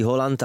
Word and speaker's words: Haaland: A nebo pokud to chcete říkat s tou norskou výslovností Holland, ale je to na vás, Erling v tom taky nemá úplně jasno, si myslím.